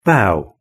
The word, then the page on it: bow: British English